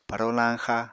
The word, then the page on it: paro lañxa: Apatani